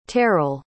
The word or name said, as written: teryl